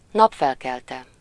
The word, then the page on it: napfelkelte: /ˈnɒpfɛlkɛltɛ/